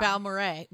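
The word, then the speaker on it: Balmorhea: Balmora. – Yeah. – Balmoray. Mm-hmm. That's how you say it.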